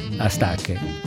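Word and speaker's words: Astatke: Mulatu Astaké, questa è l'esatta pronuncia in etiope.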